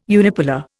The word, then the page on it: unipolar: Indian